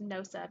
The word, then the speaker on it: Gnosa: Next, we have Gnosa, which is just pronounced Gnosa because the G is silent, meaning the secret knowledge that comes from the meditative state that connects one to the divine.